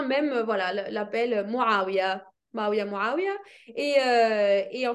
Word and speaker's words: Mavia: Ah, Maouia, M-A-V-I-A.